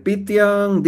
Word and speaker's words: Pythian: Entonces ustedes pueden poner en Google, eh, pueden poner Pitian Divisat. Pitian se escribe P-Y-T-H-I-A-N.